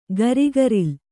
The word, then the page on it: ಗರಿಗರಿಲ್: garigaril